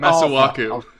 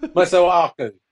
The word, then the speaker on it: Masuaku: Masueke? What's his name? – Masuaku. – Masuaku.